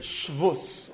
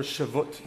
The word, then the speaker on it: Shavuos: that all of those rules for Shabbos are called by the name Shavuos, or Shavuot, depending on pronunciation.